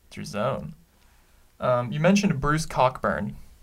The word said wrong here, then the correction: Cockburn: It's pronounced Coburn.